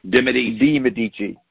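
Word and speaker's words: de' Medici: D apostrophe capital M-E-D-I. Medici. – De Medici.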